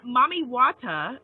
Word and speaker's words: Mami Wata: Yeah, and then Mami Wata, you know, which is how she's pronounced throughout the diaspora, but it's like Mommy Water.